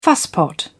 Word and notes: fusspot: British English

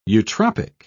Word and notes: eutropic: British English

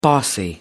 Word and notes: bossy: American English

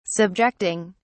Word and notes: subjecting: British English

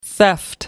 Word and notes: theft: American English

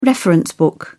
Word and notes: reference book: British English

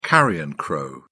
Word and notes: carrion crow: British English

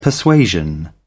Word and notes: persuasion: British English

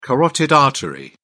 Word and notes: carotid artery: British English

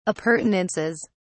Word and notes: appurtenances: British English

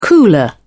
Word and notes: cooler: British English

